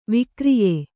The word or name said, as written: ವಿಕ್ರಯಿ